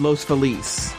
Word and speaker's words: Los Feliz: Uh, yeah, I, I, you know I read a really good article that, that only like yeah I know the proper one is Los Feliz, right? That's how you say it in Spanish.